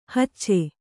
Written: ಹುಚ್ಚ

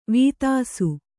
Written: ವೀತಾಸು

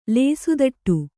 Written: ಲೇಸುದಟ್ಟು

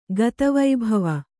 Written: ಗತವೈಭವ

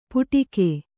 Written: ಪುಡಿಕೆ

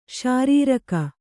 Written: ಶಾರೀರಕ

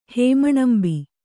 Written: ಹೇಮಣಂಬಿ

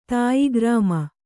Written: ತಾಯಿಗ್ರಾಮ

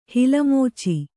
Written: ಹಿಲಮೋಚಿ

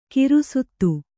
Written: ಗ್ರಸ್ತ

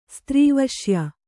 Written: ಸ್ತ್ರೀವಶ್ಯ

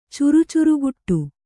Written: ಚುರುಚುರುಗುಟ್ಟು

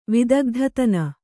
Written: ವಿದಗ್ಧತನ

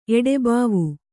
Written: ಎಡೆಬಾವು

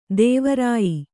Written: ತ್ವರ್ಯ

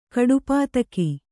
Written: ಕಡುಪಾತಕ

